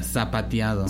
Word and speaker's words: zapateado: Yeah, zapateado. – Zapateado. Yeah. – The Z is pronounced like an S.